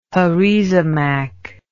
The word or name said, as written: heresimach